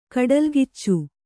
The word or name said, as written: ಕಡಲ್ಗಿಚ್ಚು